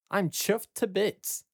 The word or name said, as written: I'm chuffed to bits.